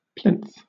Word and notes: plinth: British English

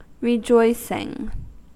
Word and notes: rejoicing: American English

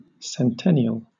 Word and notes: centennial: British English

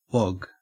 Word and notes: wog: Australian English

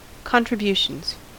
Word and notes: contributions: American English

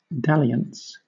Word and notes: dalliance: British English

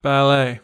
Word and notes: ballet: American English

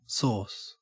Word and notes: sauce: Australian English

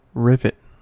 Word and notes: rivet: American English

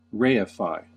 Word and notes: reify: American English

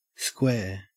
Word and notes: square: Australian English

